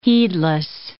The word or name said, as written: heedless